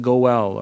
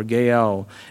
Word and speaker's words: goel: Some people call it, pronounce it Goel or Gael.